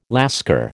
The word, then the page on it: Lascar: American English